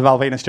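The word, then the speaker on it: Val Venis: Come on, let's keep the Val Venus jokes going. – It rhymes with penis.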